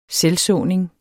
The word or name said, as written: selvsåning